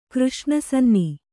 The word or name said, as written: ಕೃಷ್ಣಸನ್ನಿ